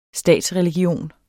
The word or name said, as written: statsreligion